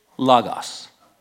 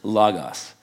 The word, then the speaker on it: logos: Oh, by the way, it's not logos, okay? In Greek, omicron, the O is never long. It's always short, so it's logos. Logos. That's how you pronounce that Greek word, logos.